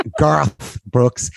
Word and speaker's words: Garth Brooks: Last thing I wanted to say was, let's get on to Gareth Brooks. I heard a great story. – I love that you got his name wrong as well. He said it's just Gareth, not Gareth. – Garth Brooks.